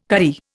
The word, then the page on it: karri: Indian